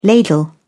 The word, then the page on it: ladle: British English